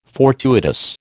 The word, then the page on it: fortuitous: American English